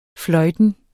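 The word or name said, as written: fløjtende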